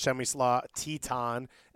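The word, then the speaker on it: Przemysław Tytoń: So you mentioned Victor Ulloa, who came in a trade, as well as Chemislaw Titon, a experienced Polish goalkeeper. – Is that the pronunciation? – That is the pronunciation.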